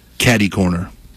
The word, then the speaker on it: catercorner: Did you know how it's spelled? CATERCORNER. It's spelled like you would say catter corner. – Uh-huh. – But the R is silent, so why is it even there?